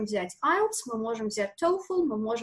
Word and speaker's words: TOEFL: Кстати, хочу обратить внимание, да, по-разному произносят коллеги этот экзамен, это все-таки TOEFL, как пальчик ноги, да, TOE, поэтому вот это правильное произношение этого слова.